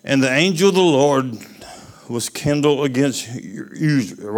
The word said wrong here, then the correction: Uzzah: What's how you pronounce that? The Uzra? Huh? Anybody want to pronounce me on that? Uzzah.